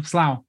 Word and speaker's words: Slough: Slough? Slow? – Got it. Second time. Slough.